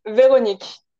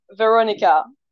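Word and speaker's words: Véronique: It's Veronique in French. – Veronique. – Yeah, but I translate my name across all countries as Veronica. Veronica.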